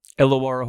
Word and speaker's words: Illawarra: But, you know, and then this past season, he spent the year with, how do I say it now, Illawarra? – Illawarra Hawks, yeah.